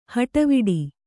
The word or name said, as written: ಹಟವಿಡಿ